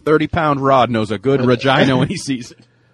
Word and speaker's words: Regina: I know Fred's not there, but I'd just like to say Regina is how you pronounce the town in Saskatchewan. It's a little more like that part of the anatomy that you guys always giggle about when that...